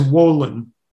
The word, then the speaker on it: Wolin: Before we do, I want to read something that was written by Philip Wallen. Am I pronouncing that correct? – I think he says Wolin.